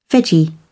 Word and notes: veggie: British English

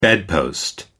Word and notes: bedpost: American English

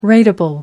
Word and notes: rateable: American English

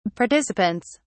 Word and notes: participants: British English

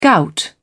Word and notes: gout: British English

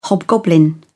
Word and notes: hobgoblin: British English

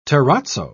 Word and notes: terrazzo: British English

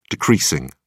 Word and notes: decreasing: British English

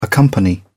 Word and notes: accompany: British English

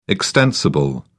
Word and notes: extensible: British English

